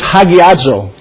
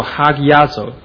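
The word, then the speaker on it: hagiazo (ἁγιάζω): The Greek word there is hagiazo. – 成圣这个词在希腊文里面的读作 hagiazo.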